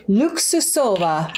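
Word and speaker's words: Luksusowa: So we'll go 1 ounce of this Luxusova. And the W-A is not Luxusova. I know it's pronounced V.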